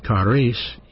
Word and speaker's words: charis: But the Greek word for grace in the New Testament, we spell it G-R-A-C-E, actually is spelled C-H-A-R-I-S, charis. Charis, that's the word grace.